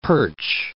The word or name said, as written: perch